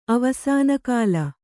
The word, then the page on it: ಅವಸಾನಕಾಲ: Kannada